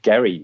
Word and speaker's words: Gerry: But his name actually was Eldridge Gerry with a hard G.